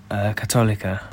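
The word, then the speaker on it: Católica: You're going to have to remind me how to say this. – Catholica. – There we go.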